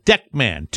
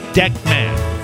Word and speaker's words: Deckman: Deckman is right here. Simple. Deckman. Two syllables. Deckman.